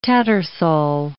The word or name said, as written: Tattersall